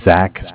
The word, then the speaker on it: Zach: Okay, my name is Zach, spelled Z-A-C-H.